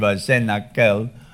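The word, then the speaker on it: Cenacle: I don't want to sound cynical, but it is pronounced, I believe, Senakel in Latin, means upper room, and then a common room for everyone, and of course, communion with God.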